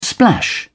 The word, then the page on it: splash: British English